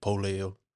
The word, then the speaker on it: Pole: Yeah, in Tongan, P is pronounced as B, like a B, so it's actually pronounced Pole. – Pole. – Yeah, I always get Pole.